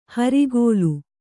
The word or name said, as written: ಹರಿಗೋಲು